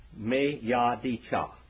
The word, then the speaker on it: Miyadecha: Okay, Achicha, A-C-H-I-C-H-A. And then the last one is M-I-Y-A-D-E-C-H-A. Me-Ya-D-Cha.